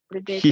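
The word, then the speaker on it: Predator: Предатор, предейтор, как правильно говорится? Ассер, предейтор.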